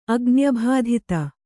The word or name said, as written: ಅಗ್ನ್ಯಭಾಧಿತ